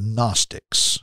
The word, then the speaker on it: Gnostics: what John was dealing with was a group of people called the Gnostics. – Mm-hmm. – Now, Gnostics, it starts with a G. The G is silent. G N O S T I C S.